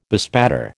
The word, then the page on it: bespatter: American English